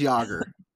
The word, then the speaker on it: Jaeger: Jaeger is Hunter in German, and it is Jaeger.